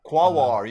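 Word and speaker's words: Quaoar: Quawar, you did it.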